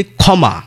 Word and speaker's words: ক্ষমা: যেমন আমরা যদি বলি ক্ষমা, তাহলে ক্ষীয় কোথায় রয়েছে? শব্দের শুরুতে। তাহলে কিন্তু এই যুক্ত বর্ণের উচ্চারণ হয়ে যাচ্ছে খয়ের মতো, পরিষ্কার খ।